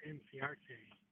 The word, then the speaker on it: Encarnacion: And to help you out, it's Ender Encarnacion. – Yeah, why don't I? Yeah, you know, I knew that too. I don't know why I keep pronouncing it the other way.